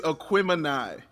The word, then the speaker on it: Aquemini: Is it not Aquamini? Have I been saying it wrong for 20 plus years? – It is not. You've been saying wrong for 20 plus years. – Damn, what is it? – It is Aquemini. – Aquemini. That's my bad syllable.